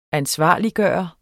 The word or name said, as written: ansvarliggøre